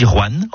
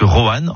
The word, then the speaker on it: Rouen: Vous avez remarqué comment je dis Rouen en une syllabe plutôt que Rouen en 2 syllabes ?